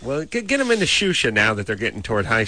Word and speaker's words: Xuxa: Get on the internet and go find Shusha. – How do you spell it? – X-U-X-A. – X-U-X-A? – Yeah.